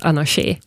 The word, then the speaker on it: Anarché: Och som uttalas Anarché, vilket då med accent på sista e-et ibland har varit ett problem för vissa.